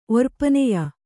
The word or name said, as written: ಒರ್ಪನೆಯ